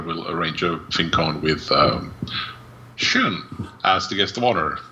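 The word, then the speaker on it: Sjón: The unpronounceable Icelander, Sean. – Yes. – Or something along those lines. – Shun. – Shun, yes. It's a shun.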